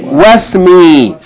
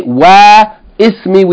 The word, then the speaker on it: wasmi: We don't say wa ismi, we just say wasmi.